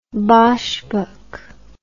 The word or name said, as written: boschbok